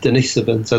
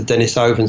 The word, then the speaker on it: Denisovans: By the way, that is the correct pronunciation. It is Denisovans as opposed to Denisovans, as some people, you know, have suggested.